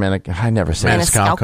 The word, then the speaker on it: Maniscalco: And this is where, I mean, you know, Sebastian Maniscalco.